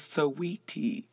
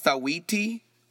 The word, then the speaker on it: Saweetie: Okay, so I just found out that this girl who sings icy, I just realized her name is Saweetie, because it's spelled S-A-W-E-E-T-I-E. I thought it was Saweetie or Sawati, but it's Saweetie.